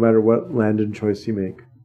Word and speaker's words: Landon: Oh, by the way, guys, I was saying his name wrong. It is Landon, like Michael. It is. I was right. But then I overcorrected into Langdon. It's Landon.